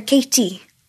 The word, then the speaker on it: Katie: Okay, so if I was going to say my name in a Welsh accent, I'd say Katie. The Welsh would go, Katie. – Okay. – So it's more softer. It's kind of more husky. Katie.